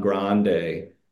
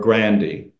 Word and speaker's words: Grandy: There's a letter that was done by Don Grande, who is or Grandy is the pronunciation.